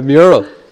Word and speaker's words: mural: Did I say it right? – A mural. – Muriel, that's what I said.